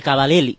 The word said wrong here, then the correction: Cavaliere: ¿cómo se dice? – El Cavaliere. – Exacto.